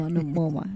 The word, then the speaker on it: Momoa: Momoa. – Oh, I thought you just called him Jason Momo. – No, Momoa. That's why. That's how his name is pronounced, right? – Yeah.